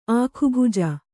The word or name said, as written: ಆಖುಭುಜ